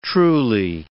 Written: truly

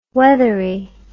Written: weathery

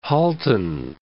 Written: Halton Hills

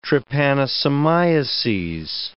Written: trypanosomiases

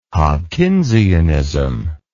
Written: Hopkinsianism

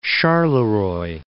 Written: Charleroi

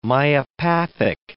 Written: myopathic

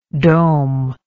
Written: domelike

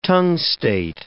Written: tungstate

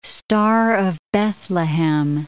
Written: star-of-Bethlehem

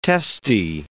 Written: testy

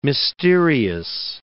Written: mysterious

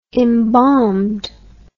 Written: embalmed

